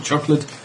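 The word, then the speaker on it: Chocolat: Fruity Five Stripes, a summer deck chair symphony of red berries, milk chocolate, and vanilla custard. – I love hotel chocolate and all their dark chocolates. – There's no E on the end. You have to pronounce it like it's foreign.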